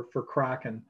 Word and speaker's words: Kraken: And I think I might have had like a cage champion or a special matches champ or something like that for Kraken, rhymes with Rockin'.